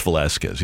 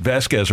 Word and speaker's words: Velasquez: So with Vasquez or Vasquez for the pitcher last night for Pittsburgh, his actual name is Vince Velasquez. He's been around for like 6 or 7 years. But Vasquez or Vasquez is the official, or maybe unofficial, Jim Edmonds pronunciation of the gentleman's name.